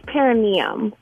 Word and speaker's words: perineum: And so it is a kind of a tricky word, but it's pronounced perineum. – Well, what did I say? – So a perineum is kind of what you said, and I'm pretty sure that's a flower.